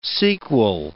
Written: sequel

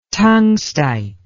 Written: tungstite

